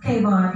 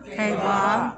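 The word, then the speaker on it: Kavah (קָוָה): And if you will pronounce it as Q-A-V-A-H, and when I listened to different studies, it was pronounced Kavah. If you'll say Kavah. – Kavah.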